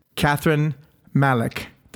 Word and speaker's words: Kathryn Malek: So, without any further ado, please welcome my next guest, Kathryn Malek.